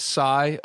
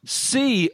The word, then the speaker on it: Sy: I had it sigh of relief. But then when you think about what his name actually is, how it's actually pronounced, sea of relief, it's not as good.